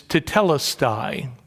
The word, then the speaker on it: tetelestai: It's tetelestai, that's how it's pronounced.